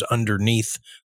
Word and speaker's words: underneath: Listen to how that TH is pronounced at the end. Underneath. You put your tongue between your teeth and blow a little bit of air. Neath.